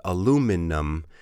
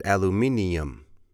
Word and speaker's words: aluminum: Yeah, the Americans, we pronounce the word aluminum. And I think in the UK, they pronounce it aluminium.